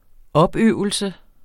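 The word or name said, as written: OPØVELSEN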